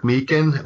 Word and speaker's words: Meechan: You can find me on Facebook, just Patrick Meekan. Last name is spelled M-E-E-C-H-A-N.